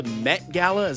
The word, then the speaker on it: Met Gala: He was at the, is it called the Met Gala? Is that how you say it? – That is correct.